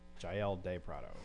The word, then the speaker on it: Jael De Prado: I think I'm even forgetting a syllable in her name. – She was scared. – Yep, I did. Jael De Prado.